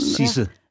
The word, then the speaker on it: Sidse: Hur uttalar man Eh, skådespelerskan på, på danska, för jag skulle säga Sidse Babette Knudsen. – Sisse. Ja. Sisse.